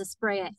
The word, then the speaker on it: Asprea: Last name is Asprea, A-S-P-R-E-A.